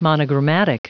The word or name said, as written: monogrammatic